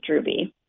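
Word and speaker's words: Droubie: The last name is Drewby. – And it's different than the way it looks. It might be pronounced, right? – Yeah. It's D as in door, R-O-U, B as in boy, I-E.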